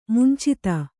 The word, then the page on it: ಮುಂಚಿತ: Kannada